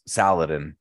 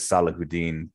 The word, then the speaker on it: Saladin: I was brought up it was Saladin, but it's the way he pronounces it is Salahuddin. But I know there's another more commonly accepted way to say that historical person's name.